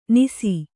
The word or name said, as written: ನಿಸಿ